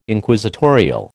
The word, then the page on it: Inquisitorial: American English